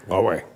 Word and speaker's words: Huawei: Huawei. – Yeah. How integrated is Huawei in the U.S. cyber infrastructure? Is that a danger, and what do you think we should do about it? – So Huawei is a Chinese company that makes switches and routers that support telephone networks and internet networks.